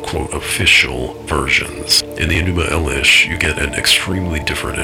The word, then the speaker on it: Elish: The Enuma Elish, or Eilish as it's sometimes pronounced, one of the oldest creation codices on the planet, describes how these beings created humans and for varying reasons.